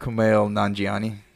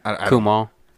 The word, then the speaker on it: Kumail Nanjiani: How do you say his name? – I don't. – Oh, no. I personally do not know. – Oh, it's like Kumail Nanjiani. – Yeah. – Kumail. – Kumail.